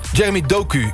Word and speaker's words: Jeremy Doku: Over, spreek je zijn naam uit als Jeremy Doku? – Jeremy Doku, ja.